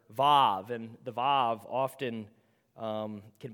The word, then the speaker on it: Vav: In Hebrew, it's pronounced Vav. The W makes the V sound, and that is the sixth letter of the Hebrew alphabet.